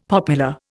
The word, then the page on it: popular: Indian